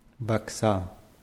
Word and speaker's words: вокзал: Вокзал. – Die Betonung ist auf A. Вокзал.